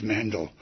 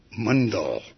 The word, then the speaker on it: Mandal: The joke here is her name is spelled M-A-N-D-A-L, and I thought she was Mandel for the longest time. But then she said, no, it's an Indian name. It's pronounced Mundell, like bundle.